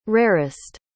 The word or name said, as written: rarest